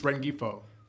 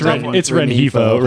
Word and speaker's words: Rengifo: Did you just pronounce the G in Ren Gifo? – I guess. How do you pronounce it? – It's Ren Hifo. Ren Hifo.